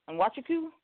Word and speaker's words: Nwachukwu: Nwachukwu, the N is silent.